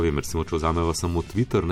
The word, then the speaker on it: Twitter: In Sploh, če bomo pa hoteli skanjati, bomo pa kako, če bomo citatno izgovarjali Twitter, ja, brez reja, z rejem, smešno bo ne in tudi nerazumljivo, tako da je bolje Twitter in Twitterja.